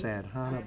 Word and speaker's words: sadhana: The basic exercise is entered as S-A-D, like sad, H-A-N-A, all one word. It looks like sad, N-A, N as in nurse, N-A. And it looks like it would be pronounced sadhana, but it's sadhana. So your accent is on the sadhana, like the H isn't even there.